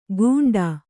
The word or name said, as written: ಗೂಂಟ